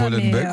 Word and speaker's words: Molenbeek: Cette maman, donc, qui a accouché dans la ville de Molenbeek. Je ne suis pas sûre que ça se prononce comme ça. – Molenbeek, oui, voilà. Ça doit être ça.